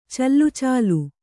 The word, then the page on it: ಚಲ್ಲುಚಾಲು: callucālu